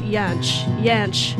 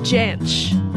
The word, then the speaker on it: Jansch: I was reading, his fans pronounce it Jansch, but he pronounced it Jansch.